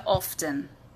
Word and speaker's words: often: ¿Qué es often? También se pronuncia often.